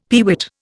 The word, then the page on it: peewit: Indian